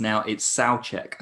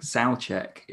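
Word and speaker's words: Souček: I watched the West Ham game the other day and it turns out that it's Soucek, I think. Soucek is how you pronounce it.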